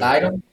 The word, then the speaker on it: Stidham: Who the fuck is Stidham? Studham. Stop. – I don't even Stidham. – know how to say it. I'll leave that to you. – Stidham.